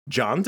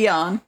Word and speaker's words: Jan: John DeBont? Or maybe it's Jan with a soft J. Jan. – It's probably Jan.